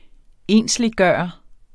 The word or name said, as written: ensliggøre